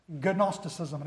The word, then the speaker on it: Gnosticism: Gnosticism, it has a silent G.